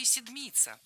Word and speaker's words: седмица: А образовано наше слово по той же схеме, что и «седмица». Так раньше называли неделю. «Семь», а по-старославянски «седьмь». Седмица, сто, старица. Ударение в обоих словах падает на суффикс.